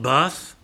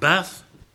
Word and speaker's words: Bath: and I must pronounce this properly, in Bath. Not Bath, but Bath. He's the minister there, and he is also the president of the Fellowship of Independent Evangelical Churches.